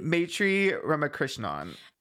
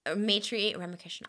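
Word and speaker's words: Maitreyi Ramakrishnan: Maitri Ramakrishnan. – Maitri Ramakrishnan.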